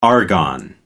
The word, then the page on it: argon: American English